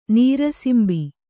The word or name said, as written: ನೀರಸಿಂಬಿ